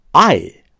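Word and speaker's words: aye: Minute sagt, I, I, nicht ich, sondern ja. I bedeutet ja. A, Y, E. I. Spricht man das aus? Wie E, Y, E. I, wie Auge.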